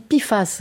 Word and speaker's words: PFAS: Allez, on referme cette page et on va évoquer donc ces PFAS, on dit PFAS, même si ça s'écrit PFAS.